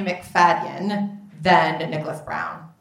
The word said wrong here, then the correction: Braun: Braun. I never had to pronounce his last name.